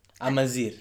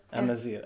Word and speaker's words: Amazigh: They use the term Amazigh. – Amazigh. – Okay, my pronunciation. – It's okay. – Amazigh.